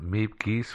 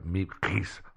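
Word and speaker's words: Miep Gies: And Meep Geese for her, let me say that right, Meep Geese, that's better, said in 1997 in an interview that she didn't think Van Maren was the informant.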